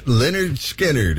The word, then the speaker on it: Lynyrd Skynyrd: That really rocks from pronounced Lynyrd Skynyrd.